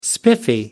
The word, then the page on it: spiffy: American English